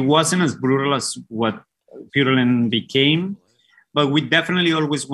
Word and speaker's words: Purulent: It's a word in English that is pronounced Purulent. – Yes, Purulent. Mm-hmm. The band Purulent.